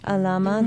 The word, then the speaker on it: Alama: Here's a song called Alama. Am I saying that properly? – Yes. – Alama.